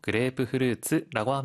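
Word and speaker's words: 그레이프프루츠라고 (グレープフルーツ): 자몽은 일본에서는 영어명 그레이프프루츠를 그대로 써서 그레이프프루츠라고 합니다.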